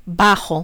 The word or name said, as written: bajo